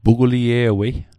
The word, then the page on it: puglie'wei: Mi'gmaq